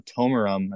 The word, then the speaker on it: Tomarum: Like their stuff, Lunar Chamber and the other one, Tomarum, I believe that's how it's pronounced. – Yep.